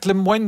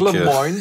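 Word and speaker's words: Lemoine: Ik denk dat ik het Lemoyne moet uitspreken. Het is een Franstalige.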